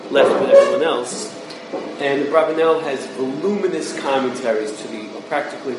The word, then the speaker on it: Abravanel: The correct way to pronounce his name is Abravanel. You could call him Abravanel.